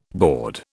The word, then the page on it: bawd: British English